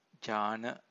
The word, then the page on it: jhana: Pali